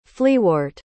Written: fleawort

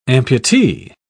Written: amputee

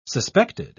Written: suspected

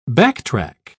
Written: backtrack

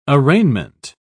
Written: arraignment

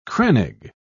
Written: crannog

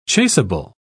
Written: chasable

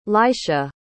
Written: lycia

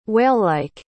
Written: whalelike

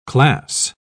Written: class

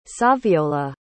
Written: saviolla

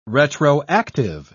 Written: retroactive

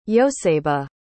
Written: yosebe

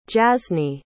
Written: jaznae

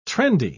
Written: trendy

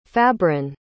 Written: fabron

